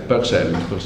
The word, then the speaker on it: Purcell: Sono due scoperte fatte indipendentemente da Bloch, non so come si pronuncia questo nome, Purcell, come si pronuncia? – Purcell.